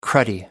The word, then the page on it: cruddy: British English